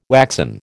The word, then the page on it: waxen: American English